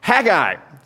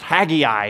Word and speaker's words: Haggai: We're going to dig out of the book of Haggai. It's also pronounced Haggai, if you aren't sure, if you're not sure how to pronounce Haggai or Haggai.